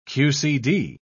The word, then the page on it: qcd: British English